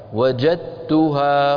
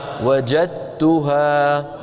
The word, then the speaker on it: وجدتها: لذلك لو نظرتم في المصحف تجدون بأن علماءنا قد الدال لم يضعوا عليها سكونا، وإنما وضعوا على التاء بعدها شدة، بمعنى أن الدال تحولت إلى تاء وأدغمت التاء في التاء فصارتا تاء واحدة مشددة. وجدتها ولم يبقى للدال أثر في النطق، هكذا وجدتها. قولوا وجدتها. وجدتها وقومها.